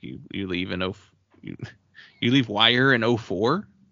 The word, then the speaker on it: Wir: They put out one record as Wire without the E, just W-I-R, but they still pronounced it Wire.